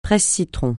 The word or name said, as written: presse-citron